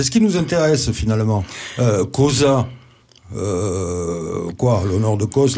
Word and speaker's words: Cosa: Enfin, on devrait dire d'ailleurs Cosa, puisque en latin, le S se prononce comme en français.